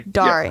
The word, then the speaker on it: Dari: Dari.